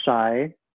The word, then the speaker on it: Shai: Because ever since, so funny story, my name is Shai, and I know I texted like it's pronounced Shai, not Shay.